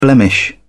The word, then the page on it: blemish: British English